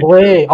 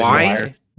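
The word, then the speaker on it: Zwei: Is that pronounced Zway? Zway? – Yeah, Zway. – Yeah, it's Zway. – Sorry, I'm not German. – Word for two, right? – Yeah.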